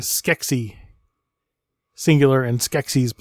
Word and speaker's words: Skeksis: So Skeksis, so they're both spelled the same, but Skeksis for singular and Skeksis, Z sound for plural.